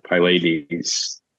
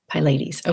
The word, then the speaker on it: Pylades: How do you say his name? – I've always pronounced it Pylades. – Pylades. Okay.